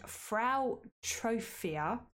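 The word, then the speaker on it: Frau Troffea: a woman called Frau Trophia. Don't know if I've said that right. That's right. Yeah, that's how it's spelled. Go with it.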